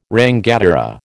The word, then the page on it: rangatira: American English